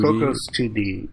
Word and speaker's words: Cocos: How do you say it? Cocos 2D? – Cocos 2D, yeah, because yeah. Cocos is actually a city, apparently. It's just it's completely coincidence that it sounds kind of like Coco. – Right. – It's spelled oh, really? – C-O-S.